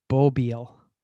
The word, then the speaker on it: bobil: They're usually, by the way, the Norwegian word for RV is bobil. Bobil. – Bobil. – Yeah. – Like bobil. B-O-B-I-L-E? – Yeah, without the E on the end. – Okay, B-O-B-I-L. Okay. – I believe that's how you spell it. Bil is a car, so it's bobil.